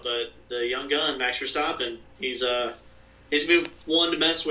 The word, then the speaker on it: Verstappen: Max for Stopping. Thanks for Stopping. Max Verstappen with a V.